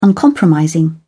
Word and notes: uncompromising: British English

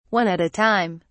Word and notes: one at a time: British English

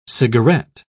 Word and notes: cigaret: British English

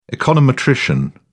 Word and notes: econometrician: British English

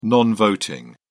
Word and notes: non voting: British English

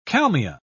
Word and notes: kalmia: British English

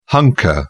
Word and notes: hunker down: British English

